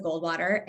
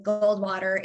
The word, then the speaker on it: Goldwater: Um, Instagram at Alyssa Goldwater, A L Y S S A. Goldwater is how it sounds you would be surprised how often I have to tell people how to spell it.